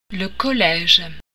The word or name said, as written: collège